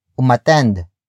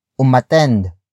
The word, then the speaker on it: umattend: Another example of this exception is umatend. Umatend. Umatend. Umatend is spelled as U-M-A-T-T-E-N-D.